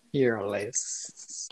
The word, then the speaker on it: Fearless: Então, o primeiro que ela lançou foi o Fearless. – Falei certo? Falou. O nosso inglês tá certíssimo. Não sei se eu falei certo. – Fearless. – Esse mesmo.